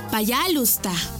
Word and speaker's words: пожалуйста: Y se dice пожалуйста.